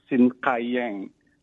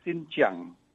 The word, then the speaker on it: Xinjiang: Uh, the last one, I know that you mentioned about the uh, the city Sinkayang just want to give you a suggestion that the the, the name of the city is it's it's it's pronounced Xinjiang.